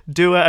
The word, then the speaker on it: Dua: And I'm laughing because Dua and Moa, when I say that, my Yorkshire accent comes out. So did I pronounce that correctly, Yawn? – Yeah, perfect, actually.